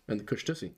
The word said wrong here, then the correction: Kushtaka: No, the Kush Taka, but... – Well, yeah. You know, I've been informed it's actually pronounced Kushtaka. – Well, there we go. Kushtaka.